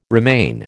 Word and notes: remain: American English

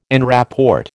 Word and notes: en rapport: American English

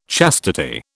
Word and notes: chastity: British English

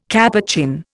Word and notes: Capuchin: Indian

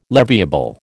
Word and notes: leviable: American English

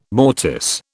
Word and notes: mortice: British English

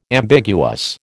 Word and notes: ambiguous: American English

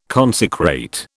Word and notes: consecrate: British English

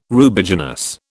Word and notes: Rubiginous: British English

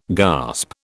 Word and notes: gasp: British English

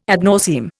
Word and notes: ad nauseam: Indian

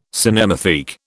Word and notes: cinematheque: British English

